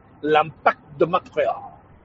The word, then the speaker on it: L'Impact de Montréal: The Montreal Impact, or as it's pronounced in French, L'Impact de Montréal.